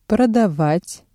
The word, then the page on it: продавать: Russian